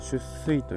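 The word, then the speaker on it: 出穂: 出水という、えー、穂が出ると出る穂と書いてですね出水と読む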